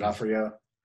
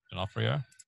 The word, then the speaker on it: D'Onofrio: D'Onofrio. – D'Onofrio.